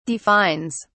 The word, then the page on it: defines: British English